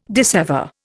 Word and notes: dissever: Indian

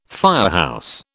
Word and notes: firehouse: British English

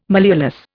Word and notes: malleolus: Indian

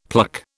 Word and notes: pluck: British English

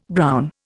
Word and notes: brown: Indian

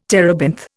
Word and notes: terebinth: Indian

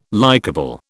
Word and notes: likeable: British English

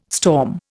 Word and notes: storm: Indian

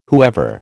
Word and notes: whoever: American English

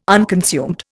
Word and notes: unconsumed: Indian

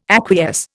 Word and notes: acquiesce: Indian

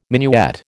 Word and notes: minuet: American English

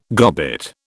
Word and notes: gobbet: British English

